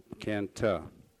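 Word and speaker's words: Cần Thơ: And tomorrow I've got to jump on an airplane early, 7:30, and go down to Canto. They pronounce it Canta, and that's the same in relation to Da Nang.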